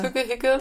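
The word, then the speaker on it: hygge: Spricht man das so aus, Hügge? – Ja, genau.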